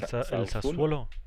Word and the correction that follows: Sassuolo: el Sassuolo. El Sassuolo. sí, correcto.